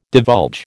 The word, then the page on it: divulge: American English